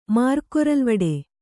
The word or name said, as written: ಮಾರ್ಕೊರಲ್ವಡೆ